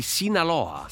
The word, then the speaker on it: Sinaloa: Esatto, vabbè, che però in questo caso il protagonista trasporta droga per il cartello di Sinaloa.